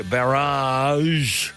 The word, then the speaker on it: barrage: Now that rowing has been confirmed for Rocky because the barrage, that's how I've been told to pronounce it, has been agreed to provide the calm waters necessary.